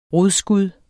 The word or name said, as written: rodskud